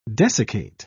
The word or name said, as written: desiccate